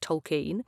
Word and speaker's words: Tolkien: First of all, I discovered that I've been pronouncing Tolkien wrong my entire life. It is pronounced Tolkien.